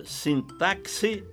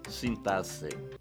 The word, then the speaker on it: sintaxe: Você pronuncia sintaxe ou sintaxe? Que eu saiba, apenas o Aurélio abona ambas as formas. Prefiro acompanhar o Ais, Caldas Aulete e o Volpe, que registram apenas a pronúncia sintaxe.